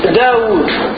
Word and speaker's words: داود: الواو من داود، لاحظوا هذيك تنطق وهي محذوفة في الخط،